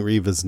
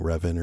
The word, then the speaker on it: Reva: So Reva, I kept getting Reva's name wrong last time. I was saying Revan or something. Sorry about that. Reva rhymes with Rima.